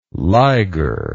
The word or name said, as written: liger